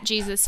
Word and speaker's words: GSIS: I honestly had to refresh Stat Jesus for, by the way, guys, that's actually what it's called. Like our media stats that we get, it's called G-S-I-S and it's pronounced Jesus.